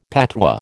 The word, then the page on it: patois: American English